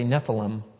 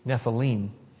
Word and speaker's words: Nephilim: It's common to hear people say Nephilim, but that's actually not the correct pronunciation according to Hebrew. It would be Nephilim.